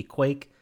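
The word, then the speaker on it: Qake: So you hit the button, and all of a sudden it like transforms you into uh Quake or I I don't know how to pronounce it. It's Q A K E. It's supposed to be Quake, but uh, a play on cake.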